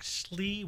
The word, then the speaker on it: Sloy: The island is in a lock called Loch Slee, which is spelled S-L-O-Y.